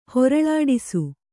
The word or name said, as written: ಹೊರಳಾಡಿಸು